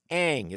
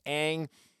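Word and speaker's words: Ng: Now, the hiring comes days after the nearby Miami Marlins hired Kim Ang. It's spelled N-G, pronounced Ang.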